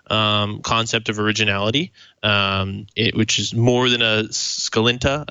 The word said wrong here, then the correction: scintilla: I think that's how – I always screw that word up. – Scintilla. – Yes.